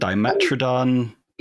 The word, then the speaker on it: Dimetrodon: I think Dimetrodon, I mean, again, there are the popular ones we've all heard of, but Dimetrodon, am I saying that right? – Dimetrodon, Dimetrodon, potato, yeah.